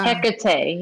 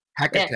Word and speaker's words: Hecate: Hecate, yeah. Hecate, yeah.